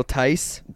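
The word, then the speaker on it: Theis: Thies. How do you pronounce his last name? I can never pronounce names. You guys know this. – Daniel Tice. – Tice. That's it.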